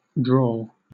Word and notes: drawl: British English

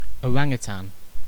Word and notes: orangutan: British English

